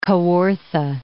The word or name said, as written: Kawartha Lakes